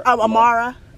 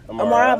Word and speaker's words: Amara: Amara. Amara, my full name's Amara Chuku.